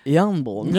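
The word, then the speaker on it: Jarnbjorn: Now, Yarnbjorn. Just don't pronounce the J and it's simple.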